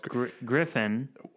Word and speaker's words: Gryphon: It's Griffin. – In my head, I kept saying Gryphon because it's pronounced Griffin, like the creature Griffin, but it's spelt like an old English spelling of the word.